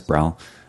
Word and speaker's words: Brel: Hi, my name is Brel. Hi. – Say your name again. – B-R-E-L, Brel. – Oh, Brel. Uh-huh. Like Jacques Brel.